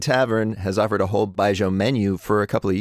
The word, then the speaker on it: Baijiu: Baijiu.